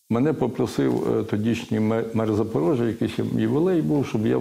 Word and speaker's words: Запорожжя: Е, Е, тому що за порогами треба мати на увазі і вимовляти правильно Запорожжя.